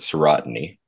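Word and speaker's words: serotiny: So at least I pronounce it serotony.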